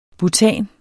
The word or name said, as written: BUTANEN